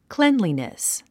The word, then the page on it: cleanliness: klénlinis; クレンリィネス